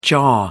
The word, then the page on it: jaw: American English